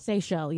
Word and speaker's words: Seychelles: Have you ever heard of the, I believe it's pronounced, it pronounced Seychelles? – Seychelles, yeah. – The Seychelles? – Yeah. – The islands? – S-E-Y-C-H-E-L-L-E.